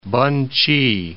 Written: Pen-ch'i

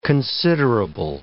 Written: considerable

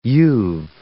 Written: you've

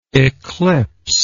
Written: eclipser

